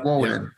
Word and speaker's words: Woden: I saw you grew up in what? Woden? Is that how you pronounce it? – Yeah. – Woden.